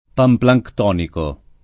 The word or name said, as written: panplanctonico